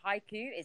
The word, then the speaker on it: haiku: And the is it haiku? Is that how it's pronounced? – Uh-huh.